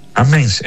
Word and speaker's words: Amiens: ¿Cómo se pronuncia? ¿Amiens o Amiens? – Amiens en francés. – Amiens. – Pero se escribe Amiens.